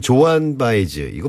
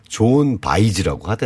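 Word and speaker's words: Joan Baez: 자 팝은 조한 바이즈 이거 뭐 들어보니까 원래 음. 발음은 좋은 바이즈라고 하더라고요.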